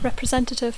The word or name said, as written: representative